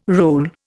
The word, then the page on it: roll: Indian